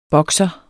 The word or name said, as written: boxer